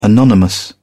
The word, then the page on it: anonymous: British English